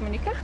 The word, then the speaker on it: Monika: No, it's Polish. You would spell it like, you know, you can spell it Monique or you can spell it Monika. – You know, my K-N-A at the end.